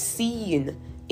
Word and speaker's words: Sin: Again, according to the Amplified Version, it is pronounced seen in Hebrew, and it means the place.